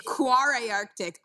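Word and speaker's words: Kvarøy Arctic: It's Quarry Arctic. I didn't know how to pronounce it for a very long time.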